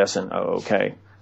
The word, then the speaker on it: Snook: And the other person is a Napa County deputy sheriff named Hal Snoke who, or some, you could pronounce it Snook. It's spelled S N O O K. As far as I know, the correct pronunciation is Snook.